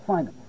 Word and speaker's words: Feynman: Richard Feynman.